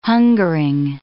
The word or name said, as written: hungering